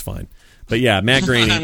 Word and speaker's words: Groening: Now, this is all very interesting, but how did you pronounce his name? – Who? Matt Groening. – Matt Groening? – Yeah. How do you pronounce it? – I didn't know. I didn't know, so I always pronounced it Groening.